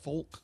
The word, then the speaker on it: folk: F-O-L-K. Folk. – The L is silent. – Yeah, you don't say folk. The L. – It's folk. – So it's folk. F-O-K-E, folk.